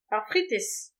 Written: ar fritez

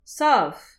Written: sav

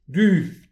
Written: du

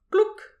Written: klouk

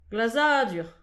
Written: glazadur

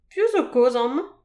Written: piv zo kozh aman

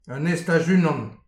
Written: an estaj unan

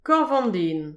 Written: korf an den

